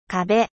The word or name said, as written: mur